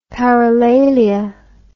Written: paralalia

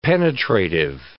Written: penetrative

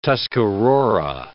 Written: Tuscarora

